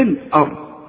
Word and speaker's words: الأرض: وإذا قيل لهم لا تفسدوا في الأرض، خد بالك الأرض دي احنا اتفقنا ان الهمزة بتاعة القرآن دائما مرققة، فما نقولش في الأرض، لا في الآ، الرحيم. في فرق اهو في النطق، ما تقولش في الا في ال، شوف حتى اللام طلعت منك مفخمة، لا اللام مرقق، في الارض.